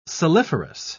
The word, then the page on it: saliferous: British English